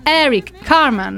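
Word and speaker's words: Eric Carmen: Ebbene, il brano All by Myself, scritto ed eseguito da Eric Carman nel 1975, si pronuncia così, in maniera così.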